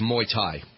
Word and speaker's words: Muay Thai: Yeah, I mean, it looks like Muay Thai, but it's Muay Thai.